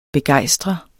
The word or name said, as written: begejstre